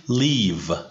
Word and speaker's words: leave: ببین زندگی کردن رو باید تلفظ کنی live. و ترک کردن رو باید بگی leave.